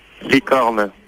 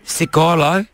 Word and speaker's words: licorne: Licorne. – Cicolo? – Licorne. – Nicolo? – Licorne. – Licorno.